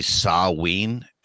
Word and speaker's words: Samhain: It's Samhain these days, I hear. We're getting close. – Speaking of Samhain, so the like proper pronunciation of that is actually Saween, and I swear to God, if anybody corrects me, I refuse to pronounce it that way.